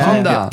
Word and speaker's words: Honda: Yeah, Honda.